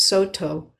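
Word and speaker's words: Sōtō: Dao Wu's Dharma heir was Dongshan, who was one of the founders of the Cao Dong school, which in Japanese is pronounced Soto, one of our lineage streams that informs Boundless Way.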